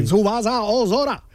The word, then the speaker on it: Tsubasa Ozora: Tsubasa Ozora.